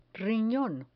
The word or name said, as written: rinon